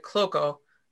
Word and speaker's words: Kloko: And it's pronounced slightly differently depending on what side you're on. So the Kloko family pronounce it Kloko, like how I pronounce it. But it's also Klokov if you're in Poland or parts of Russia.